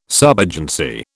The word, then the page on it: subagency: British English